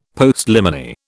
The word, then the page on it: Postliminy: British English